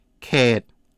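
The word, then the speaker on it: Khet (เขต): Khet.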